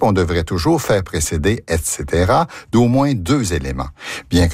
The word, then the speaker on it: et cetera: Comment doit-on prononcer l'abréviation latine ETC point? On prononce et cetera et non et cetera.